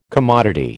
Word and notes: commodity: American English

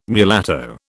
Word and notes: mulatto: British English

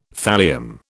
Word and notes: thallium: British English

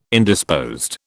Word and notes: Indisposed: British English

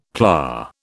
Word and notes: PLA: British English

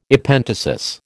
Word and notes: Epenthesis: American English

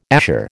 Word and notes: azure: American English

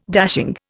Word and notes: dashing: Indian

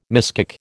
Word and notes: miskick: American English

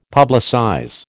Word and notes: publicize: American English

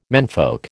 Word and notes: menfolk: American English

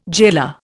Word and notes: jailer: Indian

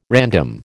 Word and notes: random: American English

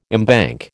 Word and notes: embank: American English

